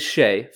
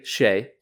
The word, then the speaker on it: Shai: So it is Shai, phonetically Shai.